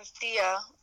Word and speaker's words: Thea: And we were talking about Tia. Or Thea, that's how you say her name, like, playing those characters, and I was like, I could totally, like, be the AJ Lee in that movie.